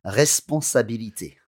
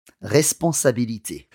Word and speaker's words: responsabilité: And that's R-E, obviously, R-E-G-A-R-D-E-R. And the word responsabilité also begins with R-E, but there's no acute accent or no grave accent in there. But when you pronounce it, let's hear it. – Responsabilité. – To me, that sounds like an R-E acute.